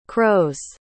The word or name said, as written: crow's